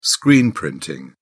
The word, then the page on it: screen printing: British English